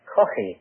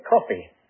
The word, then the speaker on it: Caughey: That's spelled C-A-U-G-H-E-Y. In Ireland, we call that Coffey with a guttural, but most Americans can't say Coffey, so they say Coffey.